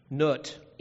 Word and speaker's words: Nut: Nut, N-O-O-T, even though it's N-U-T in the spelling would be the way to pronounce it.